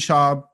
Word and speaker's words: Schaub: But yeah, for this episode, I want to introduce very funny comedian Alden Schaub.